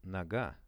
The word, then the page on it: noga: Russian